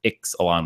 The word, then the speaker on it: Ixalan: Everyone started saying Ixalan. Then we're like, oh, the X in the Mayan dialect is pronounced the CH, which would be Ixalan. But Melissa DeTora confirmed that it is Ixalan with the X.